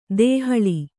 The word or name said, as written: ದೇಹಳಿ